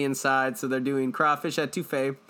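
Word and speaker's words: étouffée: Louisiana's, because we just recently went there, it was crawfish etouffee.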